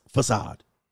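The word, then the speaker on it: façade: And of course, facade. It's pronounced facade.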